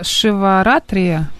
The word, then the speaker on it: Шиваратри: Короче, смотри, сегодня Маха Шиваратри, правильно я произношу? – Ну, получилось, да.